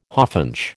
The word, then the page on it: hawfinch: American English